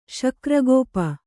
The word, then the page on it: ಶಕ್ರಗೋಪ: śakra gōpa